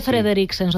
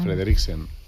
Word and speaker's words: Frederiksen: mete Frederiksen, socialdemócrata Frederiksen, Yo en Dinamarca no he pasado de pronunciar la Udrup, no puedo con el resto de...